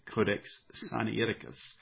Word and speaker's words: Sinaiticus: I may be mispronouncing it, but I noticed that a lot of people pronounce the first part of it simply as Sinai, and so it's Codex Sinaiticus, but I have a tendency to differentiate the vowels, and so I typically pronounce it Codex Sinaiticus.